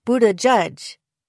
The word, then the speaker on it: Buttigieg: To me, the last syllable sounds most like the word shuzh, meaning to make more attractive and popularized lately by Queer Eye's Jonathan Van Ness. Derwing says that Buttigieg is best because it captures the stresses correctly. Using three words to demonstrate how it's supposed to sound gives the impression that all three syllables should be emphasized equally.